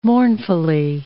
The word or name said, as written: mournfully